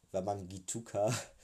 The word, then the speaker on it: Wamangituka: Wamangituka den kenne ich nicht, aber so wird er anscheinend ausgesprochen, ähm, einfach einschieben kann.